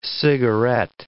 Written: cigarette

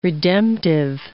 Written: redemptive

